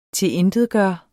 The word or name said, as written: TILINTETGJORTE